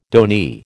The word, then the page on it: donee: American English